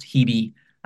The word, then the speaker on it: Hebe: So Hibe is a reference to an ancient goddess of youth. which is pronounced Hebe, H-E-B-E. We went for Hebe.